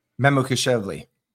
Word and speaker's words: Mamukelashvili: Mamukelashvili.